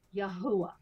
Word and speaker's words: Yahuwah: And by the way, if anyone's asking what is his name and how is it pronounced, it's Yahuwah, right.